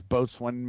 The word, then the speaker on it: boatswain: But the proper pronunciation is boatswain mate.